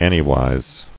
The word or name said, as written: Anywise